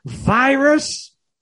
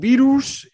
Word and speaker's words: virus: Y ya que estamos hablando de este tema de pronunciación, virus... It's not virus in English. Se dice virus.